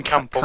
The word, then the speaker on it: Campbell: See, because it's Lars Bentner and Kempel? Is that how you pronounce it? – Kevin Campbell. – Campbell, yeah.